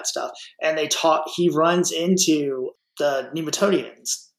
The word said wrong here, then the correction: Nemoidians: I can never say it right. Y'all, Nemoidians. Nemoidians, yeah.